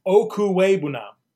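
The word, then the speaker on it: Okwuegbunam: Akwigbunam? – It's Okuwebunam. Okuwebunam. – Oh, okay. – Of course. – That's it? – Okuwebunam.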